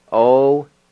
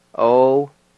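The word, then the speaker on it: ogana: And it's spelled O-G-A-N-A. O-G-A-N-A. Number two is terrapin. And it's spelled D-A-K-I-S-I. D-A-K-I-S-I. Number three is sassafras. K-A-N-I-S-D-A-T-S-I. K-A-N-I-S-D-A-T-S-I.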